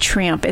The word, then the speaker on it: Treeumph: But one of my favorite places to take my kids is called Triumph. It's an adventure. It's an adventure course where it's in the trees and you climb ropes, and it is amazing. – It's spelled Triumph. – So T-R-E-E-U-M-P-H.